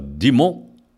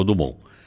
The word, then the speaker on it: Dumont: A família Dimon. olha, olha a pronúncia é Dimon. a gente fala Dumont, ficou Dumont. Mas a família Dimon já mostra que é de origem francesa.